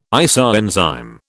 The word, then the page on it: isoenzyme: British English